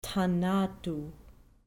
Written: thanatu

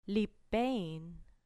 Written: lipein

